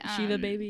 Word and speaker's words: Shiva Baby: Is it Shiva Baby? Is that how you say it? – Shiva Baby, yeah.